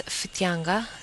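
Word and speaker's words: Whitianga: if the wannabe correct pronunciation is Fitianga, it's a Māori word,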